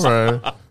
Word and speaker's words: Ralph Lauren: Ralph Lauren jean jumpsuit.